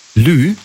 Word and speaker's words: lieu: Lieu. Also Lieu, das schreibt man also L-I-E und dann U. Lieu.